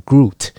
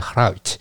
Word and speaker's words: Gruit: 那这个 Hout，它其实用英文的来发音的话，会念成 Groot。所以很多人都念成 Groot，其实这个字它不是英文，它是 Gruit，它的拼法是 G R U I T。